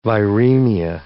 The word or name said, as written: viremia